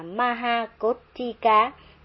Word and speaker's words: Maha Kothika: tên ngài được đọc là Maha Kothika tận cùng với chữ ca.